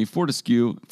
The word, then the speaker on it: Fortescue: And USA Fortescue Future Industries, a branch of the Australian mining company Fortescue. Am I pronouncing that right? – Yeah.